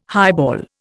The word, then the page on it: highball: Indian